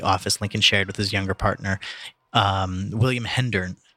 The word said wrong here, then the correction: Herndon: Herndon. Am I saying that right? – Yes. – William Herndon? – Herndon.